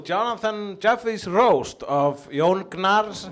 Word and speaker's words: Gnarr: It's pronounced Gnarr. – Is it really? – Yes, Gnarr.